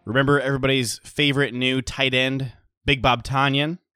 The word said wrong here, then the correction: Tonyan: No, it's Tonyan, apparently. Matt Schneidman over at The Athletic broke the news. Packers PR, he says, has alerted us that Robert's last name is pronounced Tonyan, as in rhyming with Funyan.